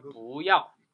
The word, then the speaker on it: 不要: 이게 부가 뒤에 사성이 있으면 이성으로 발음한다 그랬죠 그래서 不要